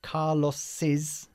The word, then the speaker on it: Carlos's: El coche de Carlos sería Carlos's car. – Mm-hmm. – Okay, we add the apostrophe after the name Carlos. We pronounce it Carlos's. – All right. – Como si fuese escrito C-A-R-L-O-S-I latina S.